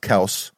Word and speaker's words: Kelce: Travis Kelce, actually pronounced Kels, Travis Kelce, one of, if not the greatest tight end of all time.